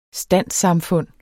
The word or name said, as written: standssamfund